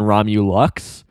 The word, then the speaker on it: Romulox: And interesting here how Dana Snyder keeps swapping between Romulox and Romulux, like L-U-X versus L-O-X. It's spelled L-O-X, so I assume it's Romulox.